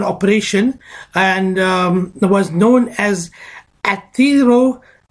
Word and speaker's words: atherosclerosis: Now, Doc, my uncle had an operation for a condition known as atherosclerosis. Now, what is this and how did he get it? – Right. I'm going to correct you a little bit there. It's called atherosclerosis. I'm going to spell it for our listeners.